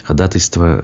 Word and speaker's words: ходатайство: Ну а так, нас тут меня, точнее, поправляют по поводу ходатайства да, по-моему, так э, и есть, вы правы. Но я не берусь утверждать, что у меня абсолютно во всех словах правильное ударение. Ходатайство, э, кстати говоря, да, да, здесь вы правы,